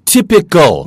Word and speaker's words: typical: Typical. – 맞습니다. T-Y-P-I-C-A-L. 이게 타입이지만 AL을 붙이면 E를 떼내고 그죠? 예, 그러면 AL을 붙이면 거기다가 우리가 IAL을 붙 ICAL을 붙이면 바로 발음이 타이피컬이 아니고 티피컬이 되는 거예요.